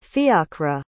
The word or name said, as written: fiacra